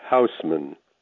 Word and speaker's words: Hausman: Hello, my name is Gerald Hausman. That is spelled H-A-U-S-M-A-N, but people love to say Hausman or Hoseman or Hoserman or Hausman.